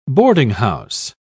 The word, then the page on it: boardinghouse: British English